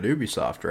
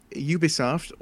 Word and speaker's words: Ubisoft: So Ubisoft or Ubisoft, it's pronounced differently depending on where you are and who you ask. Although the French pronunciation is what I've heard, it could be the correct one because it is originally a French company.